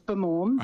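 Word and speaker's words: Bamawm: It's not as much emphasis on the first day, so it's Bermorm. That's how the locals would say it, but you're pretty close.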